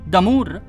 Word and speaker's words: D'Amour: Sei al bancone, davanti a una bionda carina dall'aria distratta, eh, che ti chiede: Damur? E come si scrive? Può farmi lo spelling? – Eh, come si scrive come in francese, cara. D apostrofo e poi Amour. A-M-O-U-R. – Come l'amore.